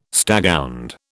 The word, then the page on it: staghound: British English